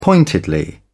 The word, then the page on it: pointedly: British English